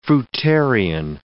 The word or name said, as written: fruitarian